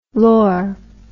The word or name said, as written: lor